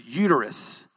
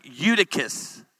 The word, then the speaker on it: Eutychus: I said this a while back, Eutychus, and I didn't say it right. I said uterus. How many of that's for a women's conference? I actually said, let's talk about the story of uterus. It's like Eutychus, Eutychus.